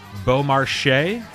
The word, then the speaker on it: Beaumarchais: Andy Beaumarchais? – Yes, sir. – I said it right. – That is right.